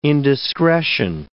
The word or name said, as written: indiscretion